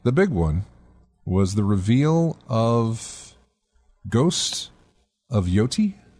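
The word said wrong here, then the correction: Yōtei: Is Yote. – That Yote? – Yeah.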